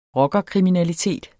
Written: rockerkriminalitet

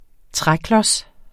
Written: træklods

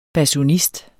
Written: basunist

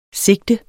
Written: sigte

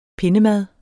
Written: pindemad

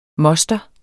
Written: moster

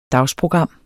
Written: dagsprogram

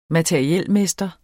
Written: materielmester

